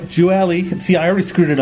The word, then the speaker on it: Gioeli: Yeah, no, actually, you did a great pronunciation. So half my family says Gioeli, and the other half says Gioeli. So there's, you can't go wrong.